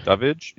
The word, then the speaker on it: Davidge: Is that how you say his name? – Yeah. – Davidge, I think. – Or Davidge or Davidge. – Davidge, yes.